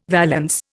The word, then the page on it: valance: Indian